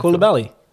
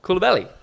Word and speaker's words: Koulibaly: No, it's Kulubabeli. Pronounce his name. I can't pronounce his name. – Oh, Koulibaly. – Koulibaly, yeah.